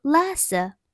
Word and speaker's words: Lhasa: 那拉萨的英文名称啊，也不是直接采用汉语拼音的 L A S A，而是 L H A S A，字母 H 不发音，读作拉萨。